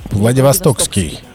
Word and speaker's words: Владивостокский: Владивостокский. Владивостокский, без буквы С, Да.